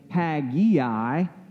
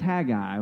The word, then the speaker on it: Haggai: Some people pronounce it Haggai, but there is no I before the A, so it's just Haggai, okay?